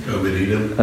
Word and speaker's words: Obed-Edom: Obed-Edom.